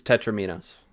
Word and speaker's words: tetromino: Instead of tables, ladders, and chairs, it's tetrominos. – You just have a C-shaped tetranimo. I can never pronounce it right. – Tetromino.